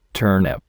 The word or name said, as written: turnip